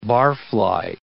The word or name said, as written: barfly